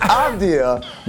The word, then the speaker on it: Avdija: Yeah, your pronunciation is nothing. It's Avdija, but this is also nothing.